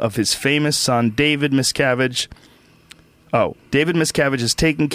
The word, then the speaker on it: Miscavige: What's the correct pronunciation? It's Cavage? Miscavige? – No, Miscavige. – Miscavige.